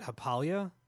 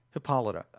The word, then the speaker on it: Hippolyta: I was wondering, how do they, I mean, how do you pronounce her name? Is it Hippalia? – Hippolyta.